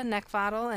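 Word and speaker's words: Neckvatal: You can also find me on Twitter, LinkedIn, and YouTube by just typing in my name, which is Brenda Neckvottle, and the last name is spelled N-E-C-K, like the thing you want to choke, V as in Victor, A-T-A-L.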